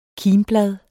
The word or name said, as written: kimblad